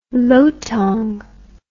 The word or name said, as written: lotong